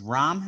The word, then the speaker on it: Rahm: And then I got Johnson over, is Rahm how you say it, I believe? – Yeah. – Yep. – Yep. – Johnson over Rahm for best finish.